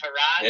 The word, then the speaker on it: Tyrod: Yeah, Tyrod.